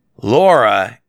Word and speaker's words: Lara: And yes, you might say, but her name is Laura, and it's spelled Lara, and everyone calls her Laura in the game, but in the movie they call her Lara.